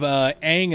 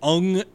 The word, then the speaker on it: Aang: Didn't they not even pronounce the main character's name correctly? – Several of the characters. Instead of Aang and Sokka, it was Ung and Sokka.